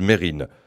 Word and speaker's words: Mesrine: Quand il parle de lui, il y a ceux qui prononcent « Mesrine » en insistant sur le « S » et ceux qui disent « Mérine ».